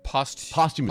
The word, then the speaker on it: posthumously: It says the Roman Senate posthumously. How do you say that word? – Posthumously. Posthumously, yes.